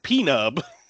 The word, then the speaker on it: P-Nub: Then we have, I thought this was, his name was going to be pronounced Nub, but it's P-Nub. – It is P-Nub. I know. When I wrote it down, I was like, all right, it's Nub. Nope. Yeah. – P-Nub. – I forgot. It's pronounced P-Nub.